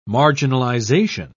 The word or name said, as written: marginalization